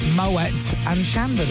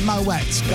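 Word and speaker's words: Moët & Chandon: And I was told yesterday by Megan, it's actually pronounced Moet and Shandon. – Yeah. – Oh, well, is it Moet? – Yeah. It's Moet. God, every day's a school day.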